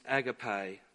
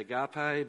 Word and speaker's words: agape: This is agape love. Some people might say agape, but wherever you put the emphasis in the syllable.